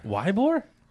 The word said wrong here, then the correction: Ybor: Ybor. – Ybor. – Yeah, I know that. But why it's spelled Wybor.